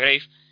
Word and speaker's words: Grave: Que de hecho muchos paracaidistas, Tumba en inglés se escribe igual que Graf, pero se pronuncia Grave, y claro, pues bromeaban o lo veían un poco macabro, ¿no?